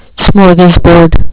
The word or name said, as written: smorgasbord